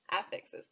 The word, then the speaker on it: affixes: So, first of all, we need to talk about affixes. Affixes, LOL. Affixes, thank you. I put the wrong emphasis on the wrong affixes.